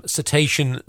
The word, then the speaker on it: cetacean: Happy cetacean spotting. Have I said that right? – Something like that. Yeah, that's about right.